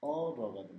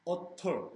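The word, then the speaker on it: otter: T를 R로 발음해. Utter. 이렇게 안 한다고. 어러. 들어보세요. 네? 들어보시면. – 어러. – 이렇게 발음해. – 어러. – 어러라고 한다고. – 어러. – T를 Utter 이렇게 안 하고 T 발음을 좀 굴려서 어러라고. 미국식 영어에서 이렇게 해요.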